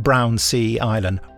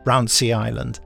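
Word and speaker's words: Brownsea Island: I love the way they pronounce it. Not Brown Sea Island. Brown Sea Island.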